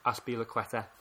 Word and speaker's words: Azpilicueta: possibly Chelsea's weak link defensively is Azpilicueta.